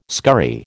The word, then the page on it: scurry: American English